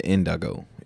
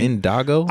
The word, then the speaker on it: Indoggo: And get the Indoggo Is it Indoggo? – I'm pronouncing it Doggo because it put the two double G's. It put the double G's in there.